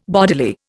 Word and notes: bodily: Indian

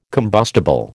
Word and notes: combustible: American English

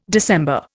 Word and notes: December: Indian

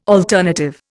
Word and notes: alternative: Indian

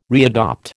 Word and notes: readopt: American English

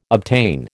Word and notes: obtain: American English

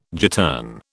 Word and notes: gittern: British English

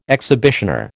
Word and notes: exhibitioner: American English

